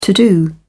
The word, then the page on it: to-do: British English